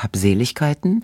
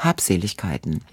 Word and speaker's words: Habseligkeiten: Und es gibt zwei Betonungsformen. Es gibt Habseligkeiten und Habseligkeiten.